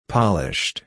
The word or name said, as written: polished